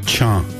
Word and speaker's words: Cha: Cha. – Cha. – Yes. Cha means rain.